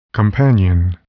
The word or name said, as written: companion